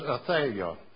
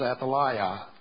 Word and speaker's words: Athaliah: and her name is Athaliah, or you may pronounce it Athaliah. It doesn't matter how we pronounce it.